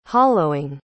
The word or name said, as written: hollowing